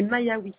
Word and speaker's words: Maïaoui: Excusez-moi si je prononce oui. – Mal. – T'as pas de souci, c'est Maïaoui. – Maïaoui Raja, c'est ça? – Raja, R-A-J-A-A.